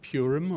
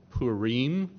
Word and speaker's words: Purim: It's although the Feast of Purim or Purim, depending on how you want to pronounce it, Hebrew or Persian, it is practiced regularly still and intentionally.